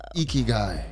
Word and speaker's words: Ikigai: Well, ladies and gentlemen, the author of Ikigai.